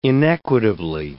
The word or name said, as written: inequitably